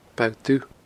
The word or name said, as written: Bo Dubh